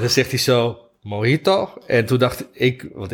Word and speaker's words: Mojito: Dus ik dacht, hey James, het is Mojito. Die J die spreekt als G uit.